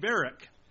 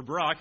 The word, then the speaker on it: Barak: Here Deborah issues a call to a man named Barak, or Barak, however you want to pronounce it, but Barak is how I'll try to pronounce it here.